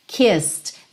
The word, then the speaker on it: kissed: Kissed. Lembra do ripped? Kissed. O E não é pronunciado. Então, do S, você vai pro D.